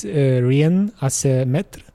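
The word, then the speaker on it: Rien à se mettre: It's Rien à ce mètre. This is how you pronounce it? – Yes.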